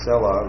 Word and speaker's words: Selah: Now this next word, after verse 2, that word Selah, they have it written Selah here. I think the Hebrew would be better pronounced Selah, at least the way it is in the Masoretic text. The vowel point is a short E sound.